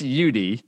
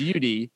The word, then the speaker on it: YouDee: it's just it's just YouDee. Oh, it's just YouDee. So it's spelled Y O U like you, and D E E. So it's YouDee with the interlocking UD in the middle.